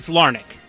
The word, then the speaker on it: Larnach: That is not how you pronounce that. Trevor Larnach. Trevor Larnach. Ah, there we go. Okay, Trevor Larnach. What'd I say, Larnach? – Well, it's Larnach. – Larnach, okay.